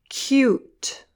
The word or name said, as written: cute